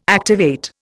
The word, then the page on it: activate: Indian